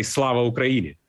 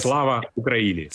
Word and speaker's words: Slava Ukraini: It's better to say Slava Ukraini. – Say again? – Glory to Ukraine. Yes. Slava Ukraini.